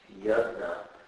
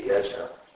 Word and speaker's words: Yajna: In India, because it's, people pronounce it many different ways, so phonetically it'd just be Yajna.